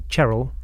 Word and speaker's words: Cherhill: And in fact, there is in the village that I spent a lot of my childhood growing up in, called Cherrill, which is spelled Cherhill, C-H-E-R-H-I-L-L.